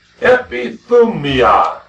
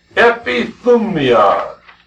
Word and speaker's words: epithumia: The Greek word is epithumia. Epithumia. It's spelled E-P-I-T-H-U-M-I-A.